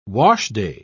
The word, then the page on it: washday: British English